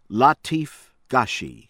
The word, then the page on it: ADEMI, RAHIM: rah- HEEM ah- DEH- mee